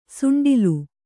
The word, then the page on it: ಸುಂಡಿಲು: suṇḍilu